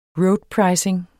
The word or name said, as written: roadpricing